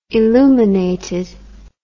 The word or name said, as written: illuminated